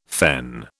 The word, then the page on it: fen: British English